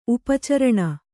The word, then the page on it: ಉಪಚರಣೆ: Kannada; upa caraṇe